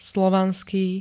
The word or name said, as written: slovanský